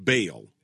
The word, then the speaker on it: Baal: They worship Baal, or Baal, B-A-A-L. In English, We pronounce it Baal. It's kind of hard to do that that Baal thing with that guttural throat sound. That's a little bit tricky.